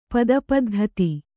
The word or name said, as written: ಪದಪದ್ಧತಿ